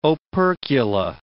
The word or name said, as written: opercula